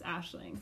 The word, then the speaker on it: Aisling: Um, if you read the podcast title or like the description, you're probably going to read my name is Aisling, but that's not how you say it. It's Ashling. Please don't say Aisling. so get it right. Yeah.